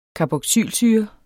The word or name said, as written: Carboxylsyre